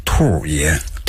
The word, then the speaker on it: 兔爷: 因为这个兔爷呢是咱们北京城的深受百姓的喜欢的一个吉祥物。它又是我们北京地区独有的这么一个吉祥物。所以我们一定要用这个北京话来读它。怎么读呢？叫兔爷。